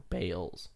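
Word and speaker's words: Baals: So they changed the pronunciation of their family's name that they've had for years to Bales.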